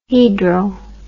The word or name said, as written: hedral